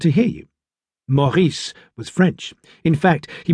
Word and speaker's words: Maurice: At the front was Maurice, the trapeze artist, whose name is pronounced Maurice, as if you are gargling an espresso of pond water.